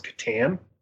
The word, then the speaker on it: Catan: I believe it's pronounced Catan.